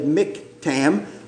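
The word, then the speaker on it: Michtam: Now, when I saw how it's spelled there underneath Psalm 16, M-I-C-H, and an accent, T-A-M, I would not have said Miktam, but that's what the pronouncing dictionary called it.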